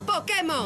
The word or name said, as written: Pokémon